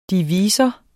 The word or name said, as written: divisor